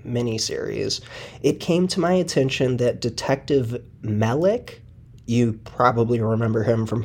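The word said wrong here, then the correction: Melich: Anyway, Melick actually pronounces his name Mellish.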